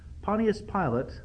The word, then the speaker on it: Pontius Pilate: At least in Pilate's case, that was true, because Eusebius, the church historian, tells us that Pontius Pilate, and again, that's the correct pronunciation, Pontius Pilate finally wound up committing suicide.